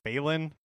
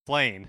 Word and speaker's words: Flayne: And you can try to catch a fish for how do you say her name? Like Falen? – Oh, Flayne? – Flayne.